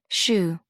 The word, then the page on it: shew: American English